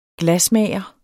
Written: glasmager

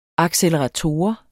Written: accelerator

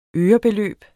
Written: ørebeløb

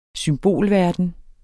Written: symbolverden

